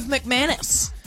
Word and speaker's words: McManus: The one, the only, Rove McManus.